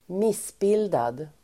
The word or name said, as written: missbildad